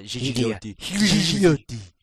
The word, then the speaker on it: Gigliotti: That's when designer Todd Alderman and engineer Frank Gigliotti, soft G, hard G, whatever, left. – Probably soft G. – Huh? Yeah, Gigliotti.